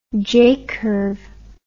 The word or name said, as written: j curve